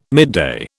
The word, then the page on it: midday: British English